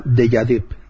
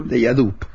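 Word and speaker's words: Déjà Dup: Se denomina Fedora, y dentro de Fedora pues existe una herramienta llamada Dejadip, o Dejadap, o Dejadip, no sé cómo se pronuncia. – Sí, como el término francés. – Como Dejadip, pero Dejadip. Dejadup.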